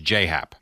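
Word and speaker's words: J.A. Happ: Right, but he wants it pronounced J-Hap.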